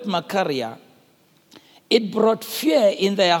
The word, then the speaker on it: machaira: Now, the word for sword there is the Greek word spelled M-A-C-H-A-I-R-A, for those of you who are writing. M-A-C-H-A-I-R-A. But when you pronounce it Makaria. Somebody say Makaria. Look at your neighbor and say, neighbor, it's called Makaria.